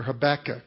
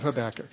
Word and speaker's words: Habakkuk: This last week in our staff devotions, we were looking at the book of Habakkuk or Habakkuk. I found out you can pronounce that either way.